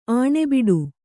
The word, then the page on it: ಆಣೆಬಿಡು: Kannada